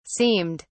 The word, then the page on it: seemed: British English